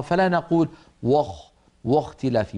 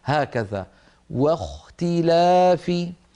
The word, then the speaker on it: واختلاف: فننبه على كلمة واختلاف في الآية 64 بعد 100، الآية الأولى التي قرأناها، واختلاف، لابد من أن ننطق الواو مرققة، فلا نقول واختلاف، بل نرقق الواو ونفخم الخاء هكذا، واختلاف.